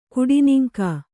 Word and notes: ಕೆಡೆನೂಂಕು: Kannada

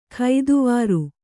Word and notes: ಕೆತ್ವಾರಿ: Kannada

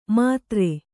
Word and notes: ಮಾತ್ರೆ: mātre

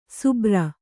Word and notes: ಸುಬ್ರ: subra

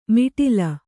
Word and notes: ಮಿಟಿಲ: miṭila

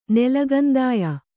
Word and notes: ನೆಲಗಂದಾಯ: Kannada

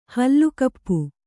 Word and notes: ಹಲ್ಲುಕಪ್ಪು: Kannada

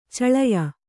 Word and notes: ಛಳೆಯ: Kannada

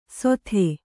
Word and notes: ಸೊಥೆ: Kannada

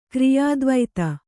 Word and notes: ಕ್ರಿಯಾದ್ವೈತ: kriyādvaita

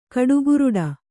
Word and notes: ಕಡುಗುರುಡ: kannada

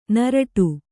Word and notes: ನರಟು: naraṭu

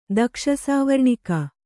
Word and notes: ದಕ್ಷಸಾವರ್ಣಿಕ: Kannada

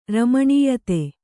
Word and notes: ರಮಣೀಯತೆ: kannada

